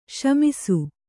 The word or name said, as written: ಶಮಿಸು